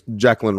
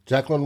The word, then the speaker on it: Jaquelin: We're here to talk about the true draft choices, picks, that the Vikings made back-to-back, Mr. Jay Ward, defensive back, and Mr. Jaquelin, is that how it's pronounced, Wesley? – I think it's Jaquelin Roy. – Jaquelin Roy, okay.